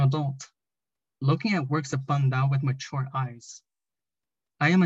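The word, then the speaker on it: Paj ntaub: Pandao, pronounced Pandao. Pandao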